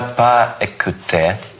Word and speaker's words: pas écouté: Je n'a pas écouté. Tu n'a pas écouté. Il n'a pas écouté. Elle n'a pas écouté. – Здесь S можно произносить.